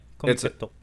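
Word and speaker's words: Comiket: It seems like there were like groups of people that would go to, I believe it's Kamiketto. Is that how you say it? – Yeah, Kamiketto.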